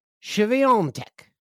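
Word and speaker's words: Świątek: I looked it up. I saw her say her own name, Iga Swiatek. Swiatek won the women's. I saw it from the person herself.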